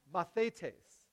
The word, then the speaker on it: mathetes: You have to get the, uh, the, the emphasis on the right syllable, right? Mathetes. Mathetes. It's a little hard to say because it doesn't make any sense in English.